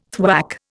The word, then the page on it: thwack: Indian